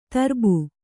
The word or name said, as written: ತರುಬು